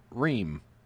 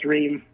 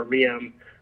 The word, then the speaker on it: Reum: So first of all, let's talk about your last name, because it's spelled R-E-U-M, but you pronounce it Reum. – Yeah, it's pronounced Reum. Everybody thinks it's Room or Reum, but it doesn't make any sense, but it's Reum. So just pretend there's two E's.